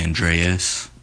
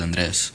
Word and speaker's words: Andres: A lot of people say Andreas, or Andre, or Andrew, or whatever. But the way it's pronounced is Andres.